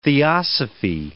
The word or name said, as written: theosophy